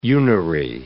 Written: unary

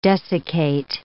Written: desiccate

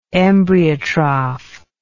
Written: embryotroph

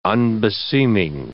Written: unbeseeming

